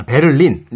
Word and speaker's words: 베를린: 우선 베들린입니다 베들린, 아, 베를린. 네. 베들린이라고 하시나 아니죠? 베를린. 베를린.